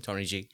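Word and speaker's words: TNYG: So it's T-N-Y-G, still pronounced the same, Tony G.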